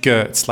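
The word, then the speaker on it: Giertz: I watched Simone Yurtz. Are you across her on YouTube? – No, she's I'm not across Yurtz. – She's amazing. It's spelled G Gertz, like G-E-R-T-Z.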